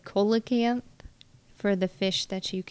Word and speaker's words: coelacanth: So that's a C that sounds like an S. – Indeed, it is. – Coelacanth. – Coelacanth. And an O E, that sounds like an E.